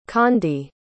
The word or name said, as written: chandi